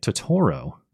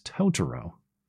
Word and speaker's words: Totoro: My apologies for previously pronouncing it Totoro. It is Totoro.